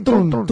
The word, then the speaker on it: Trump: Pero no se ha dado cuenta que ahora el Donald Trump, el Trump, es, o Trump, ¿cómo se dice? ¿Cómo dice el presidente? – Trump, Trump, como dice el presidente, es Trump.